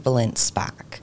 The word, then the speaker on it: Balint-Szpak: My full name is Jessie Balint-Szpak. – That's it. – Balint-Szpak, so I got two last names.